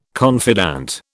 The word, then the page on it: confidant: British English